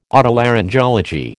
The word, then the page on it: Otolaryngology: American English